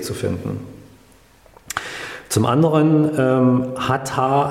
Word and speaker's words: Hatha: Ähm, Yoga, oder wenn wir den Begriff Hatha sehen, besteht aus zwei Silben, Ha und Ta.